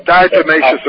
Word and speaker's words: diatomaceous: Dalmatiaceous earth. – Diatomaceous earth, yeah.